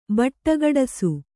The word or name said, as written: ಬಟ್ಟಗಡಸು